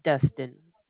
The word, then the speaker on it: Dustin: And my name is Dustin. – Could you spell that for me, please, honey? – No problem. That's D-U-S-T-I-N.